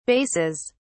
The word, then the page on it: basses: British English